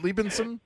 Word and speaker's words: Liebenson: Is it Liebenson? – You got it right.